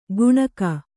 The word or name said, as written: ಗುಣಕ